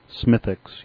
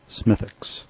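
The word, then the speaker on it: Smithwick's: So the correct pronunciation is Smithix. You don't pronounce the W in there. It's true with most things around the Britain and Ireland. Smithix, Warwick, those kind of things. You drop that W in the middle. So it's spelled Smithwix, but it's pronounced Smithix.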